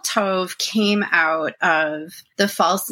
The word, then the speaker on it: Tove: And it's called Tove, right? Mm-hmm. I'm afraid I'm going to mispronounce it. Is that right? – Yeah. Church Called Tove.